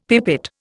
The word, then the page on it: pipette: Indian